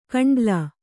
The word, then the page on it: ಕಂಡ್ಲಾ: kannada